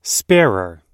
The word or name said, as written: sparer